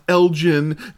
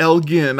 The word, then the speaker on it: Elgin: We had the live band at the Blue Box Cafe in Elgin, which, by the way, is pronounced Elgin, not Elgin.